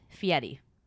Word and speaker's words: Fieri: It's pronounced Fieri.